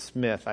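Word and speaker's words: Smyth: And this is what Jonathan Smith, it looks like Smythe, but it's Smith, I think is the way they pronounced it.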